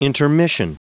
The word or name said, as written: intermission